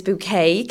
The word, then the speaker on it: Bouquet: I started Mrs. Bouquet Cleaning Services. We pronounce it as bouquet, we spell it as bucket, for a little sort of, you know, make us stand out a little bit more.